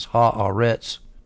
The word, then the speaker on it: ha-aretz: Now that word earth, the earth, is ha-aretz. Ha is the and eretz is land. Ha-aretz is how you say it when you put it together.